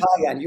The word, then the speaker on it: Pygon: Pygon, you got it.